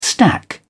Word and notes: stack: British English

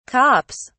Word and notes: cops: British English

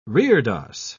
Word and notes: reredos: British English

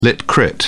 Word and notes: lit crit: British English

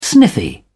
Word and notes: sniffy: English; British English

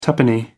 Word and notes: tuppenny: American English

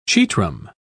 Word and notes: chetrum: British English

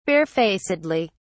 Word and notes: barefacedly: British English

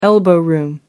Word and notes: elbow room: American English

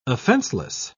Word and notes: offenseless: British English

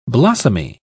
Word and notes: blossomy: British English

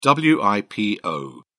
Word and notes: wipo: British English

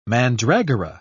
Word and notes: mandragora: British English

